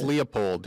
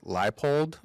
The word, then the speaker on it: Leipold: No, Lance Leopold. That's how I call him. That's not actually how you say his name. It's Lance Leipold, but it's not really spelled that way.